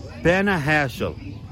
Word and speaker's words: Panajachel: They don't pronounce the J in Spanish, apparently. Yeah, Panajachel.